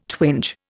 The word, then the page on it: twinge: Indian